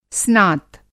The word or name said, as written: स्नात